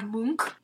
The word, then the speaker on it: Munch: There's The Dead Mother by Edvard Munch.